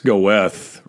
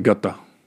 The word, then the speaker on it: Goethe: As Goethe, that's Goethe, right? Goethe, the author, thinker, right? Wrote Faust. But you pronounce it Goethe.